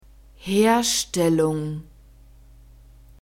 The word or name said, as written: herstellung